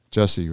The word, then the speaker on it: Jussie: No worries. Jussie, right? J-U-S-S-I-E.